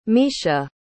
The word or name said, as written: miesha